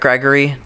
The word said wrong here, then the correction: Gregoire: Did we figure that? – Gregoire. – Is that really outside? – I'm pretty sure. – Okay, cool. We got that last time. Derek Gregoire played.